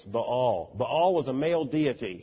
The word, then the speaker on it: Baal: In the Canaanite religion, the male deity was Baal, B-A-A-L, pronounced in good southern drawl when I was in Sunday school, Baal. They were the worshipers of Baal. Well, that's a double vowel. It's Baal.